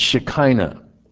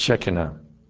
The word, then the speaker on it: Shekinah: The Hebrew for his presence is similar to the word Shekinah. And that's not the correct pronunciation. The Hebrew pronunciation is Shekinah.